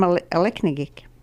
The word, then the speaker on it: Aleknagik: I'm from Aleknagik. The way we pronounce it is Aleknagik, and the root word for this term means a wrong way.